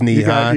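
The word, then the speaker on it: Nehi: Yeah, I could be pronouncing that wrong, but I believe it's Knee-High. – You got it right.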